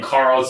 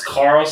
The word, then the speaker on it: Carl: It's just my name, Carl Za. That's Carl, start spelled with a C, C-A-R-L. And my last name, Z-H-A, that's Z as in zebra, H as in Henry, A as in apple.